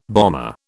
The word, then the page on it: bomber: British English